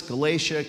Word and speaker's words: Galatia: Peter, an apostle of Jesus Christ, to God's elect, strangers in the world, scattered throughout Pontus, Galatia, Cappadocia, Asia, and Bithynia. I looked it up. That's how you pronounce those names.